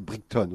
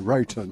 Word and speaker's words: Brighton: En Picardie, on dit Brickton, on dit pas Brighton.